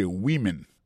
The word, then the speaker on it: women: Mais tandis que c'est le mot femme au pluriel, qu'on n'a pas prononcé woman, encore c'est women, many women.